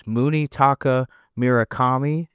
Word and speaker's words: Munetaka Murakami: The guy's name is Munetaka Murakami. And yes, I pronounced it right.